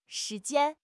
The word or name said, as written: shi2 jian1